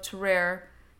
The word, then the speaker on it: Tarrare: And it's possible that our subject was given this nickname of Tarrare in reference to his very intense flatulence. – I love the way you phrased that. Also, that French pronunciation, like, boom.